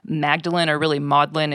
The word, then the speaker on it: Magdalen: I kind of want to talk about the Maudlin Grimoire. It's spelled like Magdalene,